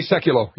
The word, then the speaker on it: Sekulow: Hey, I just heard your remark on, I think it's Seclo, the attorney. Is that how, am I pronouncing it wrong? – Jay Sekulow, yeah.